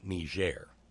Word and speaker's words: Niger: Fucking name of a West African nation that we pronounce with a soft G. It'll be fucking Niger.